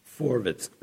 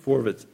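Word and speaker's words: Forwitz (פֿאָרווערטס): The Forward, pronounced Forwitz, Forwitz in Yiddish.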